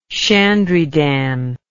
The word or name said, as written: shandrydan